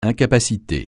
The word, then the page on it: incapacité: francais